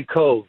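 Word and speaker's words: Cobh: And for anybody who really has family or roots in Ireland, anytime you mention, am I saying that correctly, the Cobb Heritage Center? – It's actually Cove. – Cove. – Like Lake Cove, yeah. – Like Siobhan, okay. – Yeah, like Siobhan, exactly.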